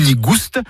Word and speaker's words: nigouste: Nigou nigouste, avec prononciation du S originel que l'on retrouve dans le provençal goustus, goûteux, et qui a été remplacé depuis par l'accent circonflexe dans notre orthographe moderne.